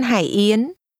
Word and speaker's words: Hải Yến: This restaurant's name is pronounced with low, falling, rising. – Hải. – And rising tones.